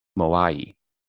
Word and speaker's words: Moai: Hangaroa is the largest settlement on what remote island, famous for its moai? – Famous for its what again? – I'm guessing this is the pronunciation. Moai, M-O-A-I.